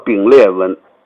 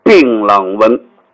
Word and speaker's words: 槟榔纹: 其实这个东西不叫丙裂纹，它的发音和丙裂纹近似，这个纹路叫槟榔纹。